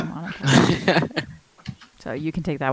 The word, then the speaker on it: Minotaur: minotaurs, or someone's going to, I think I just butchered that pronunciation, but I can't pronounce that.